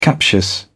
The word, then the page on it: captious: British English